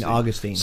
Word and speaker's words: St. Augustine: St. Augustine.